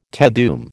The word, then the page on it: Te Deum: American English